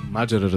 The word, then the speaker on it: Маджера: Абе, верно ли Маджера е правилното ударение? – Разбира се. – Маджера, разбира се.